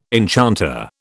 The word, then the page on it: enchanter: British English